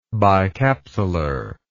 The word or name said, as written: bicapsular